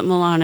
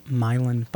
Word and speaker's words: Milan: You can call it Milan if you want. Lots of Italian people do. But it's... It was written in my neighborhood in New Orleans, which is pronounced Milan, spelled Milan.